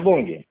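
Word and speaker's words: Bunge: A Mercedes, Yara, Suzano, JBS, Bugin, United Airlines. – Tá lá na Bung. – Da Bugin? Ah, legal.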